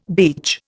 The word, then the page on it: beach: Indian